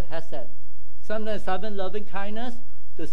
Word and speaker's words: hesed: That word is called hesed, like H-E-S-E-D. Sometimes it pronounces as K, but it's H-E-S-E-D.